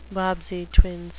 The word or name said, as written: bobbsey twins